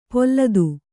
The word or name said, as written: ಪೊಲ್ಲದು